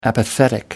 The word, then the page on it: apathetic: American English